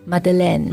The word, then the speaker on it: Madeleine: Madeleine motherland。